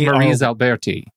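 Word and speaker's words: Maryse Alberti: M- oh Mary's no, or I, I, I thought M-A-R- it was Marie Alberti.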